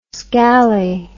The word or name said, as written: scally